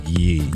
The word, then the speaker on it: Ye: Our wonderful Twitter listener, What Sleep Though, says, y'all got to stop pronouncing ye like ye. Ye is pronounced like ye in Kanye.